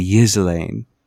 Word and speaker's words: Ghislaine: On NPR, they say Ghislaine. It's like a silent G. The G is silent.